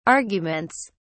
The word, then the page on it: arguments: British English